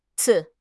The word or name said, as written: ci4